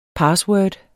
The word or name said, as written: PASSWORDET